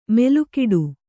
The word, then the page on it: ಮೆಲುಕಿಡು: melukiḍu